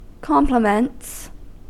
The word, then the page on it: compliments: American English